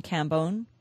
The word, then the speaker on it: Cambone: Is it pronounced Stephen Cambone? – Yes.